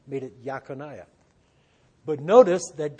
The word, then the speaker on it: Jeconiah: His full name was, you would read it as Jeconiah. They would have pronounced it as Yaconiah.